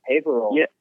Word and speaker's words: Haverhill: Favorite show probably would have to be in Haverhill, Massachusetts. Is that how you pronounce it? – Oh, yeah, that was a good one. Haverhill.